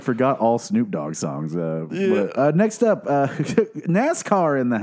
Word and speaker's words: Snoop Dogg: I might butcher this next name as Snopdog. – Snopdog. Yeah. – Snoop Dogg.